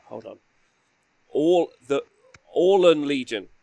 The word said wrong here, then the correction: Auralan: Well, how do you spell it? Is it A-U-R-L-A-N? – Yeah. Isn't there something between the L and the A there? On the R. I think I would just... say Aura. Aura. – Auralan. – Yeah, Auralan. Auralan. – Auralan Legion. Auralan.